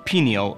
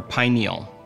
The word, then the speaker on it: pineal: Let's start with the word pineal or pineal. Both pronunciations are correct.